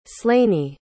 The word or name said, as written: slanie